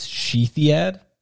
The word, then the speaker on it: Shithead: Her name was Sheathiad. – Sheathiad? What Correct. Is that? – I couldn't tell you, but it's like Greek or Egyptian. – Couldn't tell you the ethnicity, but it's spelled shithead.